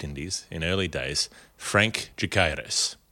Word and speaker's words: Decaires: Now, if you're confused by the name, D-E-C-A-I-R-E-S, but it's a Portuguese name, so I'll give it my best attempt at pronunciation there, I'm sure. Jacaires is how they would say it in an anglicised way.